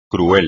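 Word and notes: cruel: español

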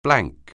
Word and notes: blank: anglais

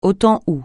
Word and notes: au temps où: francais

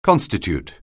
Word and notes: constitute: anglais

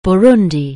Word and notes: Burundi: anglais

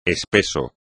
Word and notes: espeso: espagnol

